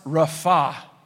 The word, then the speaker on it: rapha: And the word in Hebrew for be still is pronounced rapha.